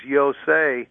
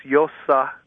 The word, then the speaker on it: Yosa: The ossuary that has the inscription that he pronounces Yosei, that's a mistake. It's the last letter that's in the name is an H. The most likely pronunciation is Yosa.